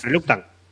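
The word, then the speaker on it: Reluctant: Claro, el otro día nos estábamos viendo el mapa del, del Reclutan Enemies. Reluctan. Reluctan, joder, siempre lo digo mal.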